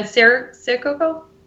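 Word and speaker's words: Sincero: And in fact, Jen, how do you pronounce her last name? Jen Sirococo? – Yeah. – Yes. She's coming to the music hall next Tuesday.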